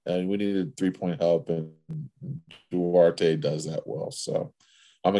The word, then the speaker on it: Duarte: Duarte.